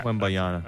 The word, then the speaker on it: Wembanyama: I definitely said it wrong. – Wembanyama? – Yeah, how there do it you, is. Wembanyama. – Yeah. – Wembanyama. – That sounds better.